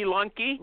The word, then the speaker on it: Lunke: Is it Lunky or Lunk? How do you say your name? – It is Rainy Lunky. Rainy Lunky.